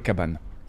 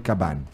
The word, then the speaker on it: Cabannes: Como é que um francês diria teu nome completo, Paulo? Paulo Cabana. Paulo Cabana.